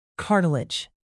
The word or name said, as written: cartilage